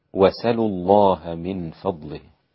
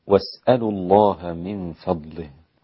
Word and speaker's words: وسلوا: وكيف يكون النطق؟ هكذا، وسلوا الله من فضله، وسلوا الله من فضله. والباقون واسألوا الله من فضله، وهي أيضا لغتان عند العرب.